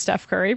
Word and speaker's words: Steph Curry: Is it not Stephon Curry? – Well, I mean, I'm assuming it's Steph Curry, right? Basketball player. – So. Yeah. It is Steph Curry.